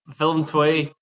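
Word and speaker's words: Willem II: Willem, how do you pronounce their name? Because I would say Willem 2. – Willem 2.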